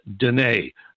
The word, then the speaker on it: Deana: Uh, her last name is spelled as if it was Diana, D E A N A, but it's pronounced Dene. Okay, so don't go trying to spell Dene. Spell it Diana, and you'll catch it.